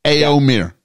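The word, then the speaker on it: Éomer: How do I say it? – Eomer.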